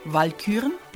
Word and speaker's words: Walküren: Walküren, Betonung auf der ersten Silbe,